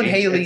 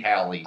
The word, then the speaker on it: Haley: H-A-L-E-Y. – Yeah. – I've known Haley. – It's freaking Haley. – I've known Haley. – If it was two L's, it would be Hallie.